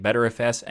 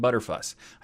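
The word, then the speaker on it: Btrfs: and turns out there is no actual pronunciation of ButterFS because there's all of them are accepted, even just saying the letters or whatever. So ButterFS is what I'm going for because it sounds fun and it's smooth like butter.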